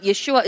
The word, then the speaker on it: Yeshua: So his name is Jesus in English, but Yeshua is the original name, and it is original pronunciation.